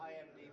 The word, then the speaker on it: IMDb: Our family set a goal that we will never watch a movie without IDMBing it. Did I say that right? – IMDb.